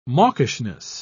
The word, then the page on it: mawkishness: British English